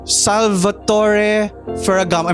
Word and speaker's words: Salvatore Ferragamo: I heard that there is another bag called Salvatore Ferragamo. Am I pronouncing that correctly? – Yeah, you're getting the hang of it, honey.